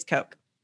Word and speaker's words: Koch: And then someone corrected me quickly and they're like, it's Coke. And I was like, okay, it's Coke. You're right. I will forever in my mind see C-O-K-E, even though it's spelled K-O-C.